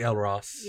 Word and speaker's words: Elros: It's probably Elros. – Yeah. – Longer vowel sounds.